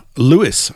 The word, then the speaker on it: Lewes: That would be down at Lewis, I think, which you may not have heard of. L-E-W-E-S. It's spelt wrong, but it's pronounced Lewis.